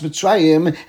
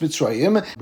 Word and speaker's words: Mitzrayim: that when one sees the word Mitzrayim in the middle of the Pasuk, so it's pronounced Mitzrayim, and when it's added a Snachta, or at the end of the Pasuk, so it would be pronounced Mitzrayim.